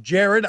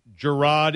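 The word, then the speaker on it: Jarrod: People are writing in, it's pronounced Jared.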